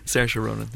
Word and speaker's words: Saoirse Ronan: Did I say that correctly? Saoirse Ronan. – Saoirse Ronan. Yeah.